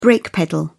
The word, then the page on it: brake pedal: British English